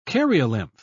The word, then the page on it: karyolymph: British English